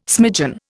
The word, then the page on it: smidgen: Indian